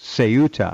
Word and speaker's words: Ceuta: supposed to be pronounced Ceuta.